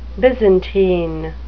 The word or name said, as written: byzantine